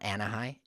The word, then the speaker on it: Anahi: So my daughter, for example, her name is Anahi, and it's spelled A-N-A-H-I. It's not Anahi. It's not Anahi. It's Anahi, and the H is silent.